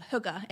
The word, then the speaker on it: hygge: And for me, the power of, I don't know if you've heard the concept of hygge, H-Y-G-G-E, people kind of pronounce it in different ways, but hygge is like a feeling that you can create.